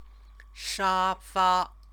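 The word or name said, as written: 沙发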